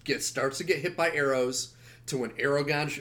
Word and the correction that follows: Aragorn: or Aragorn shows up.